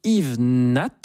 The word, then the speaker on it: Yves Nat: Et on va ouvrir cette nouvelle saison par un hommage à un très grand pianiste français du siècle dernier qui s'appelait Yves Nat, on prononce bien le T, absolument, voilà.